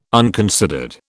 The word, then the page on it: unconsidered: British English